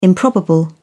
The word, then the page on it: improbable: British English